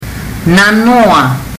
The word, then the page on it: nanoa: fiji